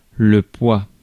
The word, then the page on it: heft: American English; [hɛft]